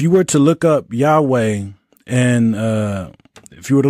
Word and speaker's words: Yahweh: We translate that word Lord from the Tetragrammaton or YHWH, and we pronounce it as Yahweh.